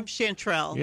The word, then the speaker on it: chanterelle: How do you say that? – I don't know. Chanterelle? – Chanterelle. – Yeah, that was close.